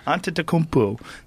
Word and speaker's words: Antetokounmpo: Antetokounmpo. – Antetokounmpo.